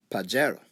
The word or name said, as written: pajaro